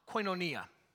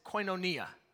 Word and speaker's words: koinonia: It's a Koine Greek word that is pronounced koinonia. Okay? I want you guys to say it with me. Ready? I'll, say it again. It's koinonia. Ready?